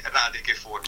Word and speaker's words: radiche fonne: Allora, parlaci un po' di intanto cosa significa rachete fonne. Immagino che sia un termine in dialetto marchigiano. – Sì, allora, la pronuncia corretta, perché giustamente magari dici no, non lo sappiamo. – Giustamente sarebbe radiche fonne.